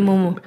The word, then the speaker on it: Mumo: de Mumo, lo dije bien, ¿verdad? – Sí, Mumo que tengo en la cabeza que se llama Momo.